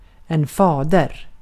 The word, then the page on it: isä: /ˈi.sæ/